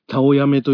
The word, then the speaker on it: たおやめ: たおやめというのは美人とか書いて、たおやめと読むのですが、このたおやめがやっぱりラスボスっぽいですね。